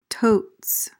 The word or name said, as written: totes